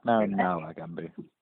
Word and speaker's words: Narumala Gumbu: So just to jump right in, been reading up a bit on the, and correct my pronunciation, is it the Narumala Gumbu project in the Children's Court? – Yep, close enough. Narumala Gumbu.